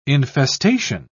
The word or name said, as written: infestation